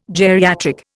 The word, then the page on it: geriatric: Indian